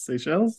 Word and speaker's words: Seychelles: How do you pronounce that? S-E-Y. – Well, that's how I always pronounce it in my head, but I've never tested that knowledge against the world. – S-E-Y-C-H-E-L-L-E-S? Seychelles? – Seychelles, yeah.